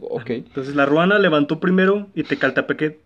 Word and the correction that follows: Tepalcatepec: te Tepalcatepec.